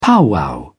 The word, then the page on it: powwow: British English